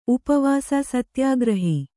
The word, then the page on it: ಸತ್ಯಾಗ್ರಹಿ: Kannada